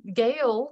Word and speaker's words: Gale: And Gale, my middle name, is spelled G-A-L-E, which is also spelled like a male.